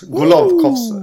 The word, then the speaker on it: Golovkovs: Eh, ska jag försöka uttala det då? Golovskovs. – Golovkovs. ja, ja, där snyggt, kom den.